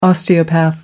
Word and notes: osteopath: American English

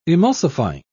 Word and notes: emulsifying: British English

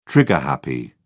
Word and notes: trigger happy: British English

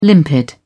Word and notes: limpid: British English

